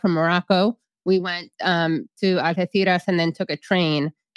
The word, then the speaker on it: Algeciras: Now, Algeciras, by the way, that's how you say it in Spain with the zeta.